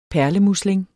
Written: perlemusling

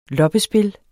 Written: LOPPESPIL

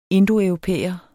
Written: indoeuropæer

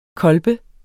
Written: kolbe

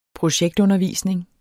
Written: projektundervisning

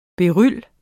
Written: BERYL

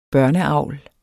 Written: børneavl

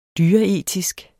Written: dyreetisk

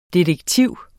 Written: DETEKTIVS

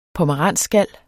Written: POMERANSSKAL

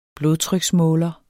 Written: blodtryksmåler

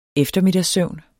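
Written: eftermiddagssøvn

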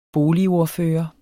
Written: boligordfører

